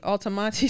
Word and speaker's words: Altamonte: I, w- I just realized I said it wrong in the past. I didn't see the T. Altamonte Springs, yeah, Florida.